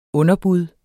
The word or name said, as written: underbud